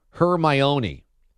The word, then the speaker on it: Hermione: It's pronounced Hermione.